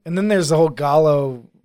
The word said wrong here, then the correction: Giallo: like movement. – Giallo. Yeah. – Yeah. I'm not Italian, so I don't know how you pronounce it. – Yeah. If you want to say, yeah, Giallo. Jilo.